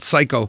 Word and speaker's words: Sycco: We finished up that three-song set with a tune from a band called, or actually it's a performer called Psycho, and it's spelled S-Y-C-C-O. Psycho is a 18-year-old pop singer-songwriter.